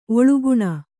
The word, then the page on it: ಒಳಗಾಣ್: kannada